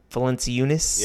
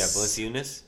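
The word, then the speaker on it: Valančiūnas: Valenciunas. I can never say his name right. – Valenciunas. – Yeah, Valenciunas.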